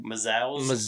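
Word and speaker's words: Mazouz: Mazows. – So yeah, that's how this is going to work. So we hope you enjoy this. – Number eight for me is the kid from Gotham, David Mazows.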